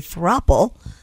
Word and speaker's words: throuple: According to The Sun, men were more eager than women to enter a throuple, with 20% of men saying they were up for a throuple. – Throuple. – Throuple. – Right. – Yeah, rhymes with couple.